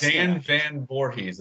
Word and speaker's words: Van Voorhis: Van Voorhis, am I pronouncing it right? – Dan Van Voorhis, yeah.